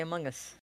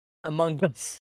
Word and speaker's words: Among Us: While the other two play Among Us. Among Us. Sorry, I had to say it correctly.